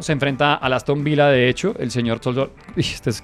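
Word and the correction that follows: Tzolakis: Es Tosolakis, ¿no? Sí, Solakis. – Solakis, porque la T es Solakis.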